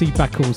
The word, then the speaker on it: CBAKL: And then tune I'm really enjoying at the moment, I think it's pronounced Cbakl, CBAKL, featuring the excellent Kibo on vocals, Meaningful Existence.